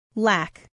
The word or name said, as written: lack